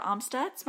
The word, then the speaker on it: Amstutz: People can find me on all social media platforms at Sandra Amstutz.